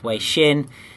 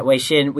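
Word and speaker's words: Weixin (微信): The Chinese name is Weixin. And so... – that's how you say it. – Yeah, Weixin.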